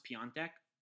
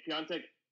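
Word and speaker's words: Piątek: So it's spelled Piatek, but it's pronounced Piontek. – Okay, Piontek.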